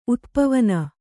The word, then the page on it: ಉತ್ಪವನ: Kannada